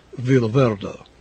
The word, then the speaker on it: Villa Verde: Oops, I should pronounce that in the Lusitanian manner, Villa Verde.